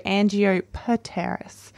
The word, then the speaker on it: Angiopteris: Oh, yes. Pteris. So Angiopteris. Angiopteris. And then evecta, evecta.